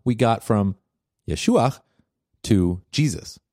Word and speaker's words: Yeshua: I'm making a point about the fact that the name Jesus did not exist at that time period, and Jesus' name was actually pronounced Yeshua.